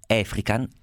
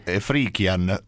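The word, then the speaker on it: Efrikian: Il giovanissimo Morandi soldatino, conosce quella che poi sarà una, sarà probabilmente la donna della sua vita, Laura Efrican, così io lo pronuncio, ma qui accanto a me ha un'altra teoria sull'accentuazione, come la chiami te? – Efrican, tra l'altro c'è le, però è come Salgari, è come un'annosa questione quella.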